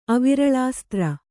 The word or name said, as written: ಅವಿರಳಾಸ್ತ್ರ